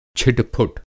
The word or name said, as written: छिटपुट